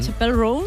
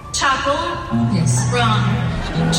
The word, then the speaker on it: Chappell Roan: Ella misma nos explica cómo se pronuncia. – Chappell Roan. – Chappell Roan. Ah, pues lo digo mal yo. – Chappell Roan. – Chappell Roan. Apple, Apple.